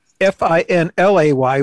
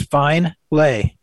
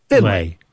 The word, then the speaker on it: Finlay: It was going to be Finlay, which could be mispronounced as Fine Lay.